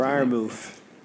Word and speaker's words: Freiermuth: Freiermuth.